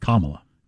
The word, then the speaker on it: Kamala: And the New York Post went with comrades for Biden and Kamala Harris because it's pronounced Kamala. – Right. Like the punctuation mark and la.